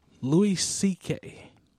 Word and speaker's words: Louis C.K.: Louis C.K.